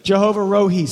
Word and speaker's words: Jehovah Rohi: So if you think about Jehovah Rohi, And again, everybody pronounces these names different. I mean, you can get online, and I have, and you click how to say it, and then it says one thing, and then it says, I'm going to say it Rohi. Good? Jehovah Rohi. Say it with me.